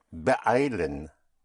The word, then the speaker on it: beeilen: To hurry oneself is sich beeilen. Beeilen. Beeilen is spelled B-E-E-I-L-E-N.